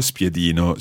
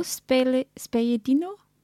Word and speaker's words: spiedino: So it's lo spiedino. So how would we say that skewer? – Quello spiedino? – It's tricky. Spiedino.